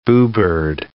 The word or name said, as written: boobird